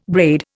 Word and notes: braid: Indian

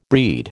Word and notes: breed: American English

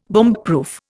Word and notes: bombproof: Indian

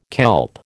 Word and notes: kelp: American English

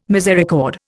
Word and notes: misericord: Indian

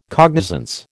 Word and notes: cognizance: American English

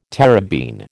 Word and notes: terebene: American English

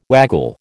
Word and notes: waggle: American English

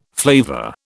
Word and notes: flavour: British English

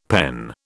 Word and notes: PEN: British English

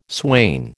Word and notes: swain: American English

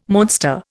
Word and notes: monster: Indian

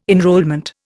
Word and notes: enrolment: Indian

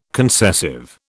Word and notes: concessive: British English

